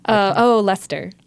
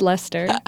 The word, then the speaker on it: Leicester: Leicester? – Leicester. It's just Leicester.